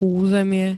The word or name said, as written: územie